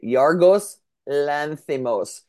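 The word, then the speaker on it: Yorgos Lanthimos: Yargos Lanthimos.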